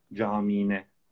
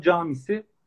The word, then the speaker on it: camisi: Yine bu ulusal kanallarda haberlerde özellikle camine, camiinde gibi telaffuzlar söz konusu. Ama camisi o i'yi de uzatmadan, caminin o sonundaki i'yi de uzatmadan camisi demek daha uygun.